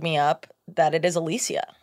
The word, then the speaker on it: Alicia: She was like, a lot of people think my name's Alicia, but it's Alicia.